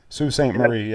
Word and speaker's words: Sault Ste. Marie: Now, after your very first place finish, you followed it up with a second place finish up on, is it pronounced Sault Ste. Marie, Michigan? – Yep. – Sault Ste. Marie.